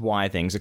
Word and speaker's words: Withings: So, now, I believe it is pronounced Withings according to Penn because it stands for Wi-Fi things. I don't know how true that is. – I believe it is for Wi-Fi things, but I think it's the same as biography.